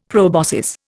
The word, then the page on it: proboscis: Indian